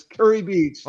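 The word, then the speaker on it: Kure Beach: It's spelled K-U-R-E. Looks like Curie Beach, but it's Curie Beach in North Carolina.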